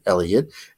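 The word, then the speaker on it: Heliot: So this week it just seems that a lot of people focusing on Elliot Ramos, outfielder for the Giants. And you spell his first name with an H, H-E-L-I-O-T, in case you're looking for it, but pronounces it Elliot.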